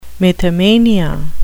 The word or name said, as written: mythomania